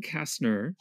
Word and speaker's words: Kastner: Um, Kathleen Kastner, is, and I did I pronounce that right as well? – Yes, Kastner.